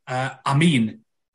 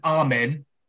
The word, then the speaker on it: Amin: I mean, my surname's of Arabic origin, Amin. And how they say it out, you know, in the Muslim world, it is pronounced Amin. As it's, you know, it's spelled A-M-I-N, but it's pronounced as if it's A-M-E-E-N.